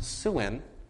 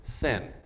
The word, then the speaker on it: Sin: Now, his name was pronounced Suin, but it was spelled and sometimes pronounced Sin.